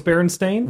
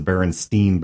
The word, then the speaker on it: Berenstain: I mean, have we been mispronouncing it? And the people who, like, yeah, wrote it welcome, say it's Berenstain?